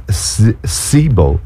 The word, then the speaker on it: Seabaugh: But this guy, Zach Sebo, and it's not like Simon Zebo, it's spelled S-E-A-B-A-U-G-H.